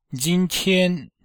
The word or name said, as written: jin1tian1